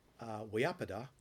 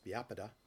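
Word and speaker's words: vyāpāda: And in Pali, it's called vyapada, which is also sometimes pronounced vyapada. V's and B's get interchanged depending on where you are in India.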